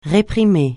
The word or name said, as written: réprimer